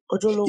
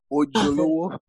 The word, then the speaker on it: Ojolowo: Huh, I said that right. – Ojolowo. Yeah, you did. Ojolowo.